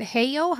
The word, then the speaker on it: Heyo: And you didn't say Halo like the angels. You said Heyo.